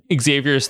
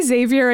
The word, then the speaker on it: Xavier: Anyway, he continues and says, "My one and only complaint about the show is that I think pronounces Xavier correctly without explicitly saying the X sound at all at the start."